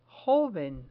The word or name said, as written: joven